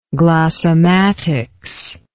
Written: glossematics

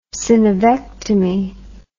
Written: synovectomy

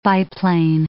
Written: biplane

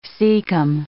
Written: cecum

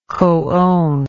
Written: coowner

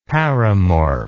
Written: paramorph

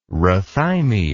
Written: rhathymia

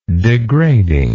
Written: degradingness

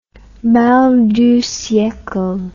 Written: mal du siecle

